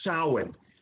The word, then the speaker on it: Samhain: It's spelt Samhain, or S-A-M-H-A-I-M, but it's pronounced Samhain.